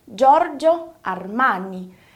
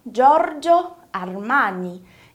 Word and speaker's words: Giorgio Armani: Giorgio Armani. Repara na pronúncia do R, Giorgio Armani. Esse som italiano vai ser sempre vibrante.